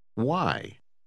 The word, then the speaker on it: why: Note que WH se pronuncia com frequência como wha. – Leia a palavra número 15 outra vez. – Why? – Número 16.